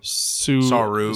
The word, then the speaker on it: Saru: And, you know, I think Suru, that's his name, right? Suru? Am I saying it wrong? – Saru. Saru or something.